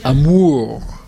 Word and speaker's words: amour: I'll say amour. It means love. So it's on the second syllable.